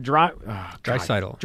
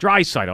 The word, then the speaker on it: Draisaitl: So there is a very talented hockey player out in Edmonton named Leon Draisaitl. – Draisaitl.